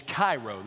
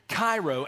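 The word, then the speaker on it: kairo (χαίρω): And remember that word in the original language is kairo. That's how you pronounce it in the Koine Greek, not modern Greek, but Koine Greek. Kairo, and it's a cognate of kara, which is joy, and charis, which is grace.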